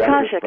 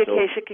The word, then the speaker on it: Akashic: Yeah, to see what I was doing wrong with this, trying to read the Akashic. I don't even pronounce it right. Read it's Akashic. It can be Akashic. It can be Akashic. Akashic. It's tomato. Tomato.